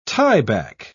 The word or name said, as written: tieback